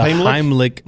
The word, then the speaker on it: Heimlich: I have a feeling the Hamlet maneuver? – Am I saying it wrong? – The Heimlich maneuver.